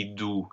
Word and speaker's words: doux: Yes, so au cri doux, the D-O-U, the X is silent in this case, but you can see that the OU combination is the phonetic U, and that's the only thing it can really be.